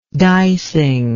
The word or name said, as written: dicing